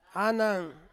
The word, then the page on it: anang: Ansus